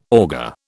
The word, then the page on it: auger: British English